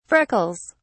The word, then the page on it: freckles: British English